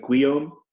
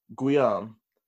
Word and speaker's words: Guillaume: Yeah, like Jacques and how do you pronounce it? Sorry, Guillaume. – Guillaume. – It's like, so, French William, yeah.